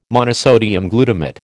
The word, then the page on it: monosodium glutamate: American English